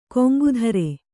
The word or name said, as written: ಗಂಗೋತ್ರಿ